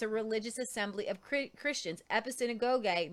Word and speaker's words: episynagoge: The word translated gather together here is episynagoge. And I know how to pronounce that because I've watched Bruce Gore several times say it. Episynagoge, which is found elsewhere only in Hebrews 10:25, where significantly it speaks of a worshipful assembly.